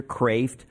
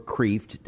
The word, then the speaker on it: Kreeft: His name is Dr. Peter Kreeft, or Kreeft, depending on whether you wish to pronounce it correctly.